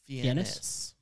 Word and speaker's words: Fiennes: Fiennes.